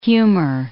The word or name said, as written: humor